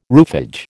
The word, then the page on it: roofage: American English